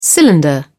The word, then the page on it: cylinder: British English